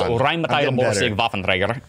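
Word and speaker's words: Rheinmetall-Borsig Waffenträger: That was actually really close. – Come on. It's Rheinmetall Borsig Waffenträger. It's a German tank that was used in World War II.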